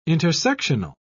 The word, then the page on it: intersectional: British English